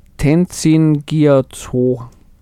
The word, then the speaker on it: Tenzin Gyatso: Ich hätte den Namen jetzt einfach ausgesprochen als Tenzin Gyatso. – Genau. Ob und stimmt.